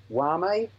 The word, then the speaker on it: Jaume: I probably mispronounced his name. First name is J-A-U-M-E, Wame Colet-Serra.